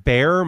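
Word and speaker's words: Baer: My name is Pat Bear. My last name is spelled B-A-E-R. I'm Pat Bear on just about everything.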